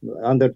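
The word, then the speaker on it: Андор: «Андер», наверное, более правильное ударение стоит на первый слог.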